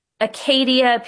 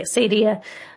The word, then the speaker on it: acedia: So acedia, acadia, people have pronounced this different ways. I'm going to say acedia.